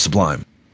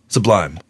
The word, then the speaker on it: Sublime: Sublime. But I call them sublime. Like Subway. It's sublime. – You put the accent on the wrong syllable.